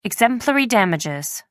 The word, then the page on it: exemplary damages: American English